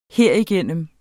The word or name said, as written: herigennem